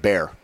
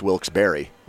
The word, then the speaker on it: Wilkes-Barre: Austin in headlines today said Bear. – I think it's Barre. – Like, I think he's looking at the way it's spelled and just going Bear, but I think the real ones know it's Wilkes-Barre.